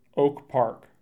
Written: Oak Park

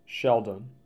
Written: Sheldon

